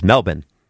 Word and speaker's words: Melbourne: And when I would say Melbourne, which is how it's spelled, Australian listeners would email us and say, that's not how you say it. You say it as Melbourne.